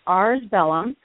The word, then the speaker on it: Ars Bellum: Nope, it's ARS Bellum, and that is Latin for Art of War.